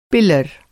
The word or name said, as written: पिलर